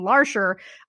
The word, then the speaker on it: Larcher: I do know enough French pronunciation to know that L-A-R-C-H-E-R is pronounced Larcher, not Larcher.